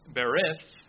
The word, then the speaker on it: berith: It's berith, if I'm pronouncing it right, B-E-R-I-T-H, and that's Hebrew. The other word is a Greek word, and it's diatheke, D-I-A-T-H-E-K-E.